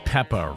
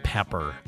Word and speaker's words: Peppa: And I did pronounce Peppa right, right? – Okay. Peppa. – Not pepper.